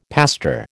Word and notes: pastor: American English

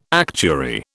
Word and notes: actuary: British English

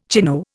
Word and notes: chino: Indian

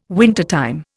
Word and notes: wintertime: Indian

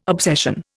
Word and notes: obsession: Indian

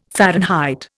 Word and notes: Fahrenheit: Indian

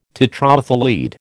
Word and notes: tetraethyl lead: American English

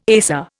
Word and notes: ASA: Indian

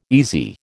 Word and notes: easy: American English

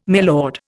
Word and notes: milord: Indian

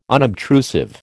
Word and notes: unobtrusive: American English

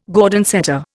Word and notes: Gordon setter: Indian

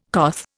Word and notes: coth: Indian

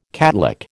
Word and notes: catlick: American English

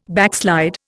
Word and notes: backslide: Indian

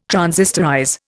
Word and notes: transistorize: Indian

